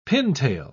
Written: pintail

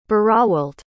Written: Berowalt

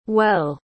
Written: hueil

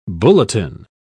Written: bulletin

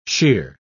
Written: sheer

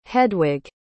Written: hedwig

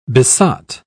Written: besot

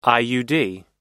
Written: iud

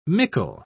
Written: mickle

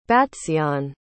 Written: battseeyon

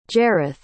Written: jareth

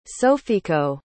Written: sofiyko

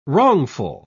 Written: wrongful

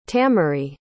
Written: tamary

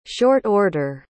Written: short-order

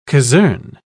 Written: casern

